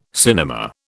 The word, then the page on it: cinema: British English